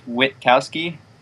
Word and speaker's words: Witkowski: It's pronounced Witkowski. Most people forget the T, but yeah.